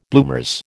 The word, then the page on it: bloomers: American English